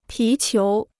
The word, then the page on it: 皮球: pí qiú